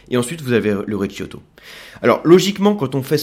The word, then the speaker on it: Recioto: Et ça c'est ce qu'on appelle le recioto. Alors ça s'écrit R-E-C-I-O-T-O. o t o le Recioto, Recioto.